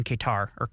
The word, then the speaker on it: Qatar: I'm pretty sure that's officially how you say it in like American English. So we're going to say Qatar.